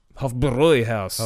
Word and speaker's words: Hofbräuhaus: Hofbräuhaus. – Hofbräuhaus. I'll have to wear this. – I believe that's... Yeah. – So we'll have to kidnap Jimmy Cro-Magnon and go back to the Hofbräuhaus.